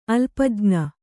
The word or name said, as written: ಅಲ್ಪಜ್ಞ